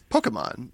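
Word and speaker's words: Pokémon: Pokemon, which is how you should say it, because it's not P-O-K-I, which is how I'm saying it, Pokemon. – Pokemans. – Yeah, it's Pokemon, and I say it wrong every time, but then, yeah.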